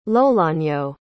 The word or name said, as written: lolonyo